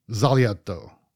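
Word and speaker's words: Sbagliato: Hopefully I get it right, but it's the Negroni Zaglia. No, that's not it. It looks like it's four syllables. It's three syllables. Negroni Zagliato.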